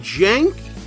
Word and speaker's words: Cenk: However, this week's nominees were on another level, as we present our case for far-left media buffoon, Hey, how do you pronounce his name? Jank? But there's no J. Okay, never mind. – Jank? – Yeah, the last name too? Okay, I'm trusting you on this.